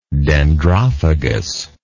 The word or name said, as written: dendrophagous